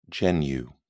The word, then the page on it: genu: British English